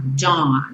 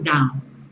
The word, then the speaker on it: dawn: Esse AU você vai falar como se fosse um O bem nasal. Dawn, não down, dawn.